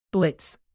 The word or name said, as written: blitz